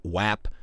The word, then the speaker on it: WAP: I get yelled at because I keep saying WAP because the word that the A represents is pronounced with an ah, not an aw.